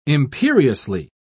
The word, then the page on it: imperiously: British English